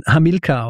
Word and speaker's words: Hamilkar: und im Fall von Hamilkar ist es eben tatsächlich so, dass man ihn Hamilkar aussprechen muss, weil es eine schwere Silbe ist, ja, im Vergleich zu Hannibal.